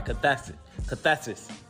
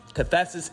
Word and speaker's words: cathexis: I'm sorry, cathesis. Cathesis is spelled C-A-T-H-E-X-I-S.